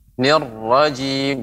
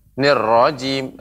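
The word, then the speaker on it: الرجيم: Nir rajim. Jadi tidak langsung nir rajim akan tapi ada tasdid maka ada lebih temponya nirrajim nirrajim ya demikian diulangi Bapak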